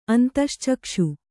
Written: ಅಂತಶ್ಚಕ್ಷು

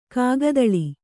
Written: ಕಾಗದಾಳಿ

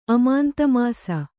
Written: ಅಮಾಂತಮಾಸ

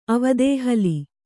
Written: ಅವದೇಹಲಿ

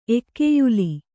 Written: ಎಕ್ಕೆಯುಲಿ

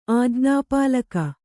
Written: ಆಜ್ಞಾಪಾಲಕ

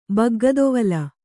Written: ಬಗ್ಗದೊವಲು

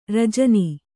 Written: ರಾಜಾಣ

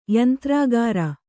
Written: ಯಂತ್ರಾಗಾರ

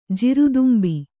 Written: ಜೀರುದುಂಬಿ

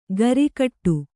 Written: ಗರಿಕಟ್ಟು

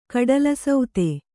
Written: ಕಡಲಸೌತೆ

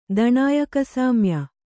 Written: ದಣಾಯಕಸಾಮ್ಯ

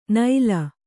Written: ನೈಲ

